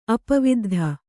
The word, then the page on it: ಅಪವಿದ್ಧ: apaviddha